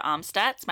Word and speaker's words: Amstutz: You can find me on all social platforms at Sandra Amstutz. My last name is spelled A-M-S-T-U-T-Z, but go follow me on Snapchat.